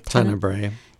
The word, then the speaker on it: Tenebrae: Before we went on the air, I was asking if they're doing Tenebrae. Is that how you say it? – Tenebrae.